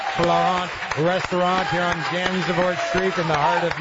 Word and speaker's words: Gansevoort: Morning Sedition live at the Cat Restaurant Florent here on Gansevoort Street. Gansevoort Street. I say it right the whole day. Screwed up at the end.